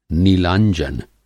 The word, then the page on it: नीलांजन: Hindi